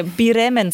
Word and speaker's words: Bremen: Bremen. – Türken und Iraner können die Konsonanten nacheinander nicht aussprechen.